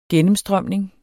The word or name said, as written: gennemstrømning